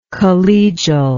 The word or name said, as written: collegialism